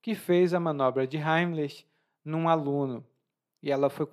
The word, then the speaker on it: Heimlich: A pronúncia Heimlich também pode ser Heimlich na pronúncia brasileira, e é uma técnica para desengasgar uma pessoa que se engasgou.